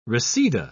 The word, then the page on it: reseda: British English